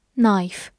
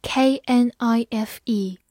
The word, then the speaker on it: knife: knife k n i f e knife k n 发鼻音 n，字母 i 发它本身的音 i，f 发 f，末尾的 e 不发音 knife knife，它是一个名词，表示刀，比如说 knife and fork。